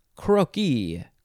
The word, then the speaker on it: croquis: Croquis, and then the plural is croquis, but it's spelled the same way. You just pronounce it differently. Croquis, croquis. Next is crore. Crore. C-R-O-R-E.